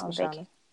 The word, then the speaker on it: Mashonda: Like Alicia Keys, Swiss Beats, and is it Mashonda? Is that how you say it? – Yes. I'm pretty sure it's Mashonda.